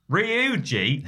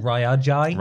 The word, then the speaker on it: Ryuji: So I'm sort of talking like, the best thing about Ryuji is... – It's actually pronounced Ryuji.